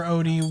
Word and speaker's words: Ody: O-D-Y. – It was O-D-Y, but it, you know, it was just pronounced Odie. – Mm-hmm. – And the meaning for Odie was, it basically meant little member.